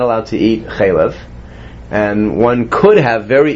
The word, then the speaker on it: chalav: Chalav. – Chalavimo. – The Chalavimo, right. – Now, the word chalav is pronounced, as we said it, chalav, with the vowels, two commas is underneath it, which is milk.